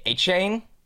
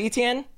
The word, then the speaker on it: Etienne: His the real pronunciation, like I believe it's the Cajun way, is A-chain. You know this with ETN? He said like people have been saying it wrong, but people still call him ETN.